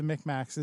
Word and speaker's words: Micmacs: and specifically about this story about the Micmacs, is that I realized that's how it's spelled, or that's how it sounds or pronounced, Micmacs. But it's spelled M-I-C. Mic is M-I-C, like Tic Tacs with M's, Micmacs. But the traditional spelling, or at least the contemporary pronunciation of the spelling M-I-C, is Mic.